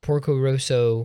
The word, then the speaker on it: Porco Rosso: Reservoir Dogs is an expertly crafted crime thriller, the twist that feels earned, and Porco Rosso, am I saying that right? – Yeah. – You guys say it. – No, you're right.